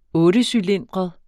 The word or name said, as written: ottecylindret